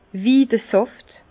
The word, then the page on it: Widesàft: Bas Rhin; Herrlisheim